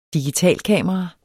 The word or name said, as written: digitalkamera